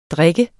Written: drik

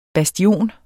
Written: bastion

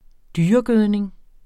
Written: dyregødning

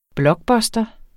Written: blockbuster

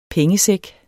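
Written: pengesæk

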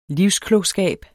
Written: livsklogskab

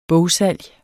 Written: bogsalg

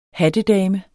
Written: hattedame

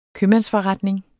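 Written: købmandsforretning

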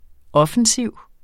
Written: offensiv

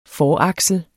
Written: foraksel

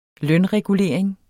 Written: lønregulering

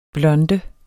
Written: blonde

